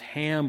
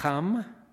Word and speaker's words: Ham: Shem, normally it's pronounced Ham, but in Hebrew it's Cham. And I'm going to say Yafet instead of Japheth.